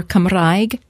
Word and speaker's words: Cymraeg: And Welsh, or Cymraeg, as it's pronounced, as it's said in Welsh, is on the uptick.